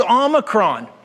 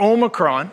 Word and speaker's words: Omicron: And on that point, by the way, people, it's Omicron, not Omicron. Everybody's saying Omicron. It's not negotiable. It's the Greek letter Omicron, which is the short O. The long O is Omega.